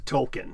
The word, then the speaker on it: Tolkien: T-O-L-K-I-E-N or T-O-L-K-E-I-N.